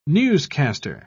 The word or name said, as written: newscaster